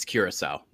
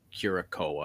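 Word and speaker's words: Curaçao: But it's Curacao, not Curacao. I don't know why people are saying that, but.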